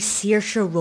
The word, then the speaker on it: Saoirse: I'm Oscar nominee Saoirse Ronan, taking it a day for GCSE film studies.